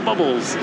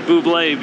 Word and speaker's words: Bublé: Bubbles. So you have been working with Michael Bubbles. Sorry, Michael, if you're listening to this, come on my show. I will be glad to pronounce it Bublé because you were kind enough to come on my show.